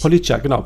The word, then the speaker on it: Police: Police, glaube ich. – Police, genau. Police spricht man die aus.